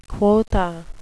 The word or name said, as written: quotha